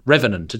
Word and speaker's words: revenant: Is that the right way to say it? or Revenant. Revenant, it is Revenant, Revenant.